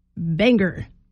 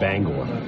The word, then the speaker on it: Bangor: Okay, so one of the biggest things that you know you're not from Maine that you can do is pronounce Bangor, Banger.